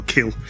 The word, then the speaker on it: Khil: Edward Kill with, I think should I say his name, K-H-I-L, Kill, with this song, I Am Glad Because I Am Finally Returning Back Home.